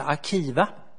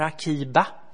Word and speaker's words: Akiva: Och det gjordes egentligen av en enda man, en rabbin som hette Akiva, eller Akiba. Det kan uttalas lite olika. B och V är ju utbytbara i hebreiska.